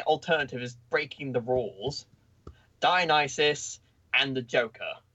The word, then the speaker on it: Dionysus: I keep getting Dionysus. – Thank you, Dionysus.